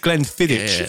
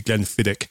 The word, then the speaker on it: Glenfiddich: I said Glenfiddich as well last week when it's meant to be Glenfiddich.